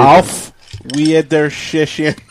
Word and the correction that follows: Auf Wiedersehen: What? Auf Wiedersehen. Auf Wiedersehen. That's how you say it.